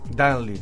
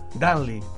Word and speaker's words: Dudley: Dudley. – Es lógico. – Todos los terminados en L-E-I es Foxley. Dudley. – Y la U se pronuncia como A.